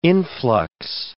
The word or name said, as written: influx